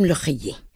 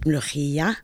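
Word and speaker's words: Molokhia: Okay, I pronounce it Molochia. Okay, and this is due to my regional accent of Northern Palestine. Some people in the south, in Gaza and in Egypt, they pronounce it Mlouchia.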